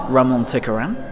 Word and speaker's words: Ramon Tikaram: It's, we have the leader is called Colonel Shindi, played by the amazing Ramon Tikaram.